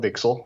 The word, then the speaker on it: VCSEL: Five times fast, or VCSEL, or let's just call it VCSEL, which is how it's pronounced.